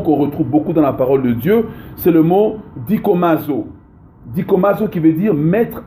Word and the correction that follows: dokimazo: Pour ceux qui écrivent dikomazo, c'est écrit comme ça se prononce. Dokimazo. Donc c'est écrit D-O-K-I-M-A-Z-O. D-O-K-I-M-A-Z-O.